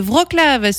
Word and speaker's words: Wrocław: Donc ça se prononce Wrocław. Je m'étais complètement planté en début de semaine. – Alors, hein. – On a entendu, hein, on avait écouté la prononciation. Je crois que c'est même Wrocław, je pense.